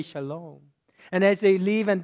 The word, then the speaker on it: shalom: actually pronounced shalom.